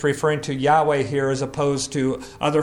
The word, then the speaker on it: Yahweh: But we would pronounce it as Yahweh.